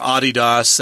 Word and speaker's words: Adidas: And they were signing him to a contract to advertise Adidas products, or Adidas. That's how they pronounce it in Europe.